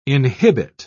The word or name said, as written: inhibit